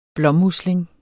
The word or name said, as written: blåmusling